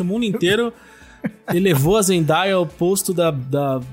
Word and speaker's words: Zendaya: Queria dizer que, infelizmente, para o meu desespero, ela deu uma entrevista dizendo que a pronúncia do nome dela é Zendaya.